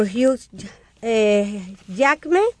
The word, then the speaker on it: Hugh Jackman: En el número 2 aparece The Wolverine con 21,7 millones de dólares, protagonizada por Hugh Jackman.